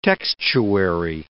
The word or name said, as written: textuary